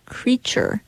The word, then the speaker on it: creature: And something that I learned when looking where this critter word came from is that technically, properly, creature should have three syllables.